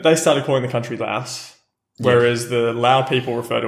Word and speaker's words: Laos: I did, in my little bit of research about the pronunciation, I was kind of informed that when you were speaking about the country in the English language, the country's name should rhyme with mouse. So, Laos would be, of course, then what you're looking for when you talk about the country.